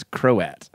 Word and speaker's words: Croat: Kraut, like Croatian. – That's Croat.